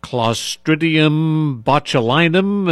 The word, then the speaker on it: Clostridium botulinum: Clostridium botulinum. Am I pronouncing that correctly? – You are, yes.